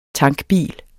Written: tankbil

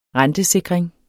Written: rentesikring

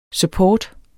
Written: support

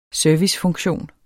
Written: servicefunktion

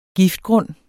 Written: giftgrund

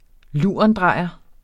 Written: lurendrejer

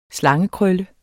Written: slangekrølle

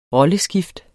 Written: rolleskift

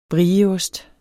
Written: brieost

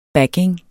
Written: backing

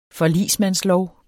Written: forligsmandslov

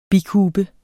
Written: bikube

